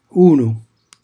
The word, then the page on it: unu: Calabrese